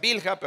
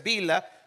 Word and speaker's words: Bilha: En inglés lo pronuncian Bilha, pero es Bila, si ya lo vemos en español.